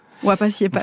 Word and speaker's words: Wapasiepas: Alors vous dites aussi, Victor Pouchet, des mots imaginaires, des séries de syllabes dont l'absence de sens vous apaise, comme Ipapupapéis et Wapayepa. – Wapasiepas. – Ah, pardon. Wapasiepas. – On prononce le S.